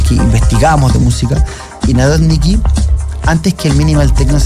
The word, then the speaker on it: Narodniki: Esa era su pronunciación, la trato de hacer en un idioma más o menos soviético, pero es Narodniki.